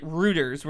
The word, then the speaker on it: Reuters: She's like, I follow r/Reuters. No. r/Reuters. What? – Reuters. – What'd you call it? – Reuters. – To be fair, it is spelled R-E-U.